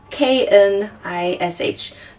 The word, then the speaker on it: knish: 嗯，它是 K A 啊 K N I S H 对，然后嗯。因为像英文里面有的时候你看到 K N 组合那个 K 好像都不发音嘛，像什么刀子就是 knife 那个 K 是不发音的。然后犹太人就他们一定会跟你说这个 K 要发音，它叫 knish。